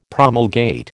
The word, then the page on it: promulgate: American English